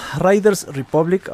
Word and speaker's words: Riders Republic: Chacatamente, entonces, Riders, Riders Republic, ahora sí lo dije bien.